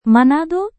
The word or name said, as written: manado